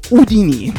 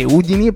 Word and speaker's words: Houdini: Cioè, no, io non so voi, ma io l'ho sempre pronunciato Udini. Perché Udini? Perché Harry Houdini evidentemente sì, si pronunciava così,